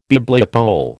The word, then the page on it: Bibliopole: American English